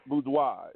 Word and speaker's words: boudoir: It's B-O-U-D-O-I-R, real? – It's boudoir. It's French for a bedroom.